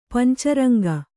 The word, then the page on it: ಪಂಚರಂಗ: panca raŋga